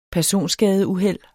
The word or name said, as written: personskadeuheld